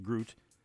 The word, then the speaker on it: gruit: She spells it G-R-U-I-T, pronounced Groot, which is an ale based on the findings of digs in Celtic areas.